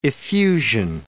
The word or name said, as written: effusion